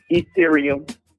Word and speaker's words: Ethereum: Ethereum.